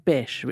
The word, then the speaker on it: Bish: Anyway, he's called Mr. Bish, which rhymes with fish, which is quite entertaining.